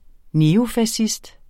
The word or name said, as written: neofascist